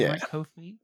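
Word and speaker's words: Ko-fi: Oh, you got Kofi as well. I said that right. Kofi? – Yeah.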